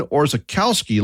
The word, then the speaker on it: Orzechowski: Ben was born today in 1947 in Cleveland, Ohio, and he had a long Polish last name that some folks pronounced his last name as Ben Orzechowski, like it looks.